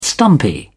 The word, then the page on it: stumpy: British English